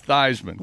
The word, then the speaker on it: Theismann: But if you ask the University of Notre Dame PR department, it's Theismann because it rhymes with Heisman.